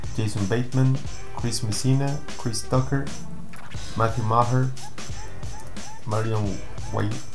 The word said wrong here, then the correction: Marlon Wayans: Ah, ¿cómo se pronuncia? Marlon Wayans. – Marlon Wayans.